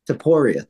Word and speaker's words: Topuria: Topuria.